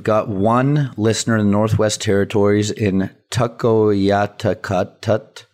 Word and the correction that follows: Tuktoyaktuk: Probably butchered that. – One that people say Tuktoyaktuk, isn't it? – That might be it.